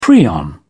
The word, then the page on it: prion: British English